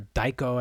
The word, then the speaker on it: taiko: Yeah, and a lot of the taiko drums that we're hearing are daiko, I think is closer to the right pronunciation, are really these samples that are sometimes pre-laid.